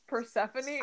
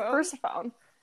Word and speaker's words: Persephone: Yeah, and also that it's pronounced Persephone. – I still pronounce it Persephone because it's the only way that my brain can comprehend it. – Whenever I need to like spell it out, like phonetically, I say Persephone.